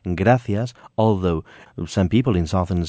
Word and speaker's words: gracias: Apart from saying gracias, we will not pronounce the final S, so we will say gracias. – Ah. – Buenos días, which would be like good morning, buenos días, we would say buenos días.